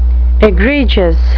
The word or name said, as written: egregious